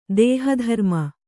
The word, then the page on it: ದೇಹಧರ್ಮ: kannada